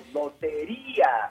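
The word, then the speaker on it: Lotería: You have to accentuate the I, Loteria. But, you know, I've been getting that a lot with the interviews. People, here I say Loteria.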